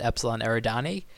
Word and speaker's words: Epsilon Eridani: I guess I have pronunciations because I listened to it, and they call it Epsilon Eridani. – That was fine.